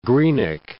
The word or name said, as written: Greenock